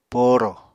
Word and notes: poorò: Apatani

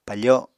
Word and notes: pafo: Apatani

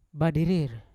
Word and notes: badirir: Yawa; Unat

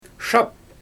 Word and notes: ʃaap: Xyzyl

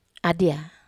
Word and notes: adia: Wamesa

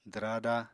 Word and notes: dwraada: apatani

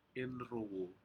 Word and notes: inrowuh: Aneityum

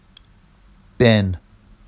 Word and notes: ben: Vafsi

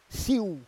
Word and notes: siu: Roon

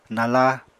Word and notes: nala: Apatani

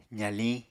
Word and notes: zaliñ: apatani

